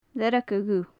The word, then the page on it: learga dhubh: gaelic